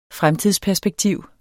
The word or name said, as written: fremtidsperspektiv